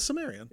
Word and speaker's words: Cimmerian: Oh, that this this is Cimmerian. Is it Cimmerian? Yeah. Okay, I would I never knew if it was oh, a soft C or a hard C or yeah.